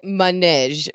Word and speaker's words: manège: So I would say manège, because of the way there's what's called an accent grave over the E. So it's M-A-N-E, accent grave, G-E. So I would say manège.